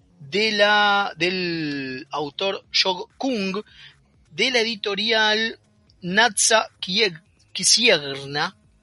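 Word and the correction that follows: Księgarnia: A ver si lo dije bien. Kisigarnia. Ahí está.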